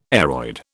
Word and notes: aroid: British English

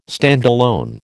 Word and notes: standalone: American English

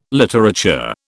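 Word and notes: littérateur: British English

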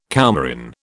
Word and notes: coumarin: British English

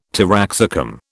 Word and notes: taraxacum: British English

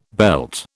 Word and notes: belt: British English

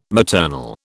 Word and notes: maternal: British English

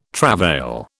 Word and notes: travail: British English

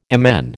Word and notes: MN: American English

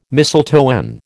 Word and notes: mistletoe: American English

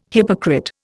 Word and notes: hypocrite: Indian

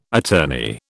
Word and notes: attorney: British English

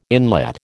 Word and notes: inlet: American English

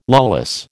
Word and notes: lawless: American English

